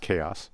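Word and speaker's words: KAO=S: It's spelled K-A-O. There's an equal sign and then an S. And through the magic of the internet, I wrote to them this morning, and in fact, it's pronounced Chaos.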